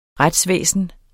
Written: retsvæsen

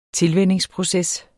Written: tilvænningsproces

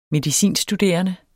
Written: medicinstuderende

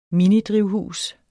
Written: minidrivhus

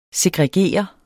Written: segregere

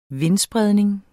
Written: vindspredning

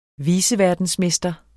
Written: viceverdensmester

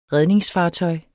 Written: redningsfartøj

